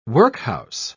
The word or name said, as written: workhouse